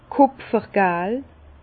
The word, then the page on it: kùpfergal: Bas-Rhin; Herrlisheim